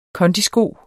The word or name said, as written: kondisko